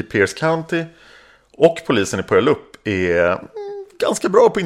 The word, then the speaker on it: Puyallup: Puyallup, alltså det här namnet är Jag kommer att säga fel på det. Det uttalas förmodligen inte så här. Men nej, Puyallup.